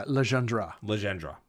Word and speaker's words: Legendre: And throughout this movie, the zombies do the bidding of Murder Legendre. – Imagining how it's spelled. – It's legend with re at the end. – Yeah, Legendre. There you go.